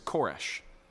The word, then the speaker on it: Koresh: Now, in the Hebrew of Isaiah, this word here for Cyrus is Koresh, but it's incredibly close to the Persian pronunciation of their king, King Cyrus, who conquered the known world.